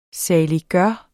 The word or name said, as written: saliggørende